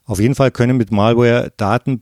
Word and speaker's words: Malware: Den Begriff Malware haben Sie bestimmt schon mal gehört oder vielleicht auch äh, etwas breiter US-amerikanischer ausgesprochen Malware oder auch Schadsoftware ist der Überbegriff.